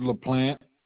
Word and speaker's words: LaPlante: Richard LaPlante.